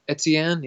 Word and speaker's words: Etienne: Travis Etienne, am I pronouncing that correctly? – Etienne, yeah, I think so.